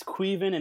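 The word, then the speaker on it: Caoimhín: I think his first name is like Queven, which sounds nothing like how it's spelled. – Yeah, it's Caoimhin. – Apparently it's Queven, and it's like the Irish version of Kevin.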